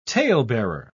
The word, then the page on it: talebearer: British English